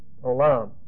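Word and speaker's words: Olam (עולם): This is not pronounced. This first consonant is not pronounced in Hebrew. Olam.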